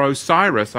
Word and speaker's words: Osiris: But Osiris, or Osiris, I should say, poor pronunciation there, I apologize, and Mithras were both called Christos, which means good.